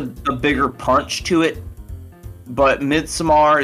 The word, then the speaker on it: Midsommar: Then we can make a porn version of Midsommar.